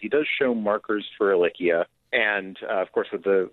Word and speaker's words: Ehrlichia: The vet has subsequently, we've had several conversations with the vet about Lyme disease and about, I think it's Ehrlichia. Am I saying that right? – Yes, you are.